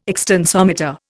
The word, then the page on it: extensometer: Indian